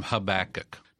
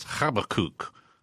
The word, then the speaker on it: Habakkuk: Well, I thought it would be interesting, especially in light of all the current events, to go with, we call him Habakkuk. – Now, I've heard other people pronounce it other ways. – Yeah, the Hebrew, the way you pronounce it in Hebrew is Habakkuk. – Habakkuk? – Habakkuk.